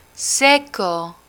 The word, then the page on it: seco: spanish